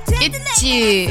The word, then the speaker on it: ITZY: Itzy. – I think it's Itzy. – Itzy. – Right. – Well, in Korean, they don't have Z in Korean. It's just J. So they say it like Itzy to mean like they have it.